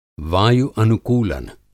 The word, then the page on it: वायु-अनुकूलन: Hindi